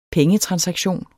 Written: pengetransaktion

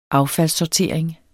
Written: affaldssortering

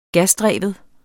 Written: gasdreven

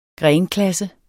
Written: grenklasse

